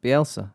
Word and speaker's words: Bielsa: Sammy Lee, who is the assistant West Brom, assistant boss at West Brom, he had a unique request for Marcelo Bielsa. – Yeah. – Am I saying that right? – Bielsa? – Bielsa, sure.